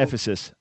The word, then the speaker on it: Ephesus: Paul goes to Ephesus. I hope I pronounced that. – Yeah, to Ephesus.